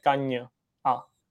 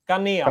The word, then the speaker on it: Kania: On by to teda měl být Kanny a jako s tvrdým, a ne Kaň a. Jo? Říkal to v nějakém rozhovoru. Že to je takže jako... máme říct, takže ho vyslovujeme jak? – Jako kdyby tam měl Y. Kania.